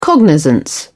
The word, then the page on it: cognizance: British English